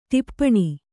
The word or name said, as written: ಟಿಪ್ಪಣ